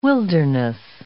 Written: wilderness

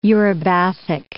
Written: eurybathic

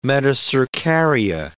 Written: metacercaria

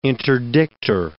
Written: interdictor

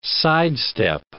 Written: sidestep

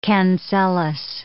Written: cancellous

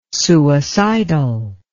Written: suicidal

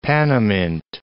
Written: Panamint Mountains

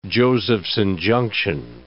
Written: Josephson junction

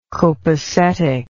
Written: copasetic

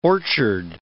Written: orchard

